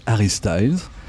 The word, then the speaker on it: Harry Styles: si tu veux bien, sur cette tournée, cette aventure un peu folle avec Harry Styles.